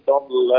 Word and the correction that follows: ضل: رقق اللام ضل. – إن ربك هو أعلم بمن ضل عن سبيله. – ما زالت يعني انطق الكلمة وحدها، قل ضل.